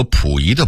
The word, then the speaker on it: 溥仪: 当然有些纯属俄化的字音我们还应该发正音，比如府和谱两个字，就是杜甫的甫和溥仪的溥这俩字儿。很多人把杜甫的甫念成谱，把溥仪的溥念成甫，这是必须要纠正的，咱不能姑息错字啊。